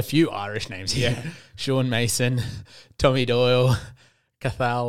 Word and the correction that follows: Cathal: Lanigan. Did I pronounce that right? – No, Cahill.